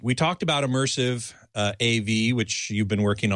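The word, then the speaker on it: ImmersAV: immersive. And I must say, it's pronounced just like the word immersive, but it's spelled I-M-M-E-R-S, capital A, capital V.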